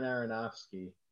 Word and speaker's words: Aronofsky: You know, Darren Aaron, how do you say his last name? The guy who did Requiem for a Dream. Darren Aronofsky. – Yeah.